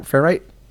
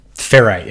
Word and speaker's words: Ferrite: Is it one word, just like that? Ferrite. – Ferrite, yeah. It's spelled Ferrite. – Oh, whoa! – It's an audio. Yeah, ITE.